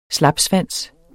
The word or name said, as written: SLAPSVANSENE